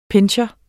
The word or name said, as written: pinscher